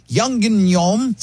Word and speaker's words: Yangnyeom: In Korean cuisine, Yangnyeom. – Spell it again. – Wait. – Y-A-N-G. N-G. – N-Y-E-O-M.